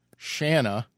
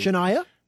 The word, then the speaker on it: Shanna: So I've got a local candidate, and her name is, and I want to say this correctly, it rhymes with banana, Shanna. She was very clear to say. – Shania? – No, Shania. Shanna. – Okay. – And her mom always said, rhymes with banana, Shanna Banana, Danielson, okay?